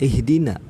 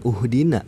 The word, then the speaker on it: ihdina: kenapa kita bisa tahu kalau tulisan eh kalau itu dibacanya ihdina bukan uhdina bukan ahdina. Selanjutnya, uhru uhruh.